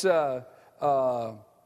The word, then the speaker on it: neshamah: And it's neshama. N-E-S-H-A-M-A-H is the way we would spell it. Neshama.